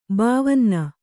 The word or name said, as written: ಬಾವನ್ನ